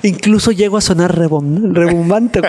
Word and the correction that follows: rimbombante: ¿Cómo se dice esa palabra? – Rimbombante. Rimbombante.